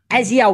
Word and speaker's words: Isaiah: The most random celebrity supporter has ever emerged, and it's, how do you pronounce his first name? – Isaiah Whitlock Jr., a.k.a.